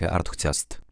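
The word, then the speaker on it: UrtheCast: Urthetest wymawia się tak samo jak Arthetest. Pozwoli oglądającym wyszukiwać i oznaczać różne miejsca, przewijać zarejestrowany obraz, przybliżać, oddalać i w pewnym sensie sterować kamerą.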